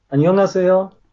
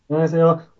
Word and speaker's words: Annyeonghaseyo: Annyeonghaseyo. Ah, okay. – Annyeonghaseyo. Und man sagt, je schneller man sagt, desto natürlicher klingt's, glaube ich. Man verschluckt dabei einige Silben, aber wenn man so Annyeonghaseyo es gibt kaum Widerstand so beim Sprechen und mhm.